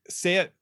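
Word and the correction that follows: Shayetet: 13. Am I saying that wrong? – Shayat Shayat 13. Shayat 13.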